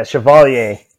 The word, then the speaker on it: Chevalier: Chevalier? – God damn it. Really? Fucking ass. – Yes? – Yeah, Chevalier.